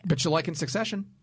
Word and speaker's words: Succession: I have a hard time with my hard C's. – Ah. – Succession. – That's good. – That was right.